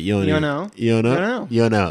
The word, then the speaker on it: UOENO: U-O-E-N-O? – Yeah. – How do you pronounce that? – You know. – That's right.